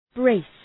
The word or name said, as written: braced